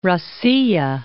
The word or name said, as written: Rossiya